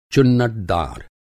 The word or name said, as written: चुन्नटदार